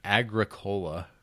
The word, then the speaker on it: Agricola: It's spelled like it's spelled like Agricola. – Oh, I've heard it pronounced Agricola.